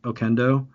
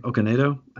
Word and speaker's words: Okonedo: It had Ralph Fiennes, aka Voldemort, and Sophie Okendo. Or not Okendo, I think Okonedo. I don't know how to pronounce her last name.